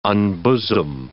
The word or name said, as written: unbosom